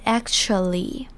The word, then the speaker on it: actually: 接着我们来看 actually 这个字，actually 这边字首的 a 是念成 a。 a 的声音，而后面的 t 的念法比较特别，是念成像 ch 一样 ch 的声音，所以是 actually。